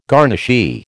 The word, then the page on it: garnishee: American English